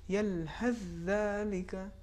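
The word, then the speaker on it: یلهث ذلک: تو تلفظ یہ ہوگا یلحث ذالک، بالکل حرف سا کا تلفظ نہیں ہوگا، اس کے جگہ پر حرف دال کا تلفظ ہوگا.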